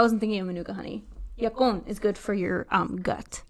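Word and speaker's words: yacón: I think it's yacon because it's from South America and there's a little accent on the O. So yacon.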